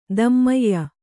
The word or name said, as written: ಧೂಮಾಯ